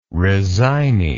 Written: resignee

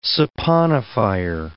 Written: saponifier

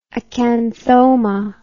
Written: acanthoma

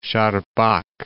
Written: Charpak

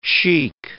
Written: sheikh

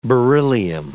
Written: beryllium

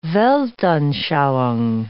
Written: weltanschauung

